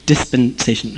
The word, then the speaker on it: dispensation: Dispensation. That's how you say it.